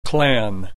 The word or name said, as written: clan